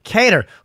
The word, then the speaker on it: Kattar: Renato Moicano against Calvin Kattar. Am I saying his name right? – Cater.